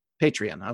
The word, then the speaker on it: Patreon: Whether it's like a GoFundMe thing, hey, I want to put up, you know, create a website that has this, there's the, how do you pronounce it? The Patron or Patreon, whatever, where you know what Patreon. – That is? Patreon. Okay.